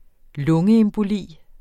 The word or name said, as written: emboli